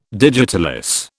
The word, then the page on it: digitalis: British English